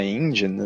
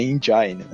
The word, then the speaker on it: engine: O, essa, esse lance que eles falam que eles reutilizaram a engine, assim, o que a gente chama de engine, não, desculpa, estou pronunciando errado, é engine, né? – Engine.